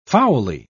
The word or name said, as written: foully